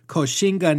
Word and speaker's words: Koxinga: And that Koxinga name was derived from the Hokkien pronunciation of one of his titles, Guoxingye.